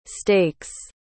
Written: stakes

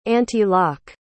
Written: antilock

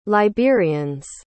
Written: liberians

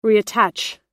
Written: reattach

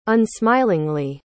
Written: unsmilingly

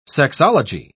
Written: sexology

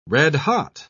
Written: red-hot

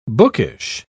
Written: bookish

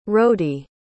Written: rody

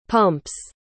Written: pumps

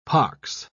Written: pox

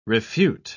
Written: refute

